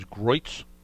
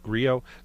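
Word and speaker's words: Griot's: I, I always thought it was Groitz Products, but it's it's pronounced Griot.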